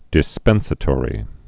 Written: dispensatory

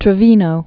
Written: Lee Trevino

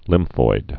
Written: lymphoid

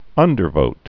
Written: undervote

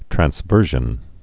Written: transversions

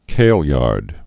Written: Kailyard School

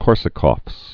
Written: Korsakoff's syndrome

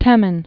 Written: Temin

